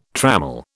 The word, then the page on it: trammel: British English